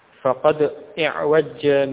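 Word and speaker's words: فقد اعوج: Sukun pada dal yang awal tersebut, sukun yang awal pada huruf dal di kasrah. Faqadi' wajja. Dan tidak tasdid ya. Bukan faqadi'.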